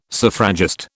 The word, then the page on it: suffragist: British English